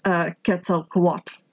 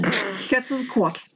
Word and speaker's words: Quetzalcoatl: I think it's actually pronounced Quetzalcoatl. – Quat. Yes, perfect. – Quetzalcoatl.